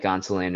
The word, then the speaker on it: Gonsolin: They did it with Gonsolin.